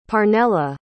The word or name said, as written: parnella